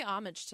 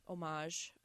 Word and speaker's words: homage: And they pay homage to that for sure. – Mm-hmm. – Homage. I always pronounce that wrong. – I say homage.